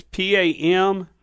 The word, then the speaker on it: Pamplona: Okay, the Pampeluma, Spain question? – Yes, sir. Here's the question. – How do you spell that town now? – It's Pamplona. – Pampeluna? – Pamplona. – Pamplona. – I think I added an extra syllable? – Vowel in there.